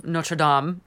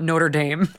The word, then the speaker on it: Notre Dame: I'm sorry, I mispronounced it before. It's The Hunchback of Notre Dame, not Notre Dame.